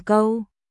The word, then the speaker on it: Câu: Không phải chó, là ngựa con, phải đọc là câu cơ, gâu cẩu chó. Còn tên của Trần Câu là câu trong mã câu ma gâu, nghĩa là ngựa con á mọi người.